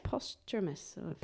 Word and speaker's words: posthumous: Posthumous. – Yes. – Is that the word? Did I say it right? – Yeah. It was.